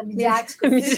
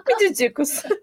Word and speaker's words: midiáticos: Nós vamos chamar a Lana, a mulher oficial desses recados midídicos. Como é que fala? Midiáticos. – Midiáticos.